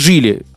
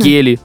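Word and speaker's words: Geely: Джили. Кто-то называет Гили, прикинь? – Серьезно? – Гели, я слышал. – Ух тышка. – Да, Гели. Хотя Джили уже сколько на рынке у нас, давненько.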